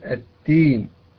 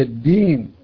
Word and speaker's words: الدين: أيضا البعض ينطقها داء تاء، وخصوصا ننتبه إلى سورة الفاتحة في قوله تعالى مالك يوم الدين، نسمع البعض يقول التين، هي الدين وليست التين، فكثير من المبتدئين في تعلم القراءة يقلبها تاء، وذلك لعدم المحافظة على شدتها وجهرها، فتنقلب معه تاء.